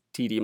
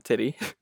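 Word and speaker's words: titi: They're from UC Davis, and they did this really cool experiment with TD monkeys. That's how you say it, not titty. Or at least I think it's not titty.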